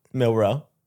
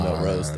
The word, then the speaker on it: Milroe: What is it? – Milroe. I like that you call him Mel Rose, though.